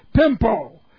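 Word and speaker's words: pempo: And that word is pimpo. Pimpo. P-E-M-P long O.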